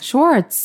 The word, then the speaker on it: Schwartz: Why do you have to pronounce a W so hard? – Babe, you say Schwartz. – Schwartz. That's how you pronounce it.